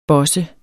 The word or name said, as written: bosse